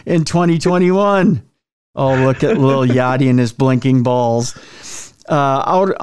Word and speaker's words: Yachty: No, it's Lil Yachty.